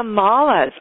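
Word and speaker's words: mala: Is it malice? Is that how you pronounce it? – Oh, the malas.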